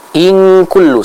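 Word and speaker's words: in kullu (إن كل): Jadi in kullu sempurna eng, bukan iya iya, jangan mengambang suaranya, tapi kita baca dengan apa? Dengan sempurna ng-nya kalau dalam bahasa Indonesia, ya. Kita baca eng.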